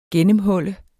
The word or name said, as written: GENNEMHUL